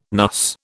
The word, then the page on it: NUS: British English